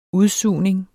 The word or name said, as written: UDSUGNING